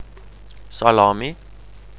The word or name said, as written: salami